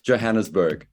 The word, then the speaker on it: Johannesburg: Is that okay to say it with a hard J? – I like the hard J. – How do you say it? – Johannesburg. – Oh, you do say it. Okay. – Yes. So oh, yeah. I thought it was like Johannesburg. – No.